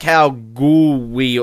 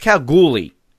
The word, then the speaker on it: Kalgoorlie: But anyway, they say it wrong. They say like Kalgoorlie. The way they say the word, it is not how you pronounce it. It's Kalgoorlie, everybody.